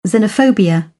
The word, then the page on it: xenophobia: British English; əʊ